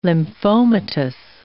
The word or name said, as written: lymphomatous